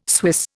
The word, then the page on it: Swiss: Indian